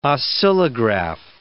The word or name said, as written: oscillograph